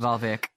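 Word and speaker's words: Waalwijk: I did quite like the bottom of the league side, RK Walwick, I think it's pronounced. – I think it's Valvik, perhaps. Valvik. – I think it's Valvik. – Is it Valvik, is it?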